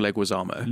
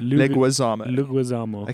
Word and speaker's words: Leguizamo: How do you pronounce his surname? – John Leguizamo. – Leguizamo. Okay.